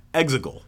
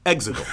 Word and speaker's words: Exegol: You're still not saying it right. It's Exegol. Exegol.